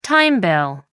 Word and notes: time bill: American English

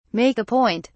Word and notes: make a point: British English

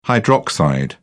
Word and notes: hydroxide: British English

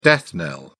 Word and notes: death knell: British English

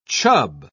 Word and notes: chub: British English

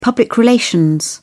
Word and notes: public relations: British English

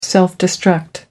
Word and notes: self-destruct: American English

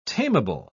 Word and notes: tamable: British English

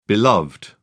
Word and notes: beloved: British English

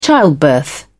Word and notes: childbirth: British English

